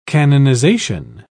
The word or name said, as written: canonization